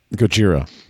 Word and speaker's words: Gojira: Gojira's spelled with a J. – Yeah, it's spelled that way because that's how Japanese people pronounce Godzilla.